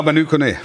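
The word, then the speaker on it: Manu Koné: Ja, Manu Kone. Wolf, jetzt hat das denn richtig gesagt? – Gefeiert. Ich habe Manu gesagt. Es ist richtig.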